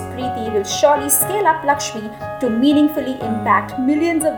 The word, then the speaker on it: LXME: And her most recent venture and passion is Lakshmi, which is spelled as L-X-M-E.